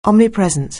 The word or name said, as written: omnipresent